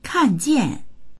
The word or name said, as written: kan4jian4